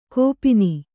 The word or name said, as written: ಗೋಪನ